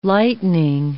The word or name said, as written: lightening